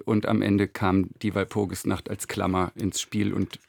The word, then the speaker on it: Walpurgisnacht: Groß N und eine 8. Was hat es damit auf sich? – Ja, das war eine Idee von unserer großartigen Grafikerin Nicole Winterbauer. Es spricht sich aber einfach Walpurgis Nacht und ist, glaube ich, ein Eyecatcher.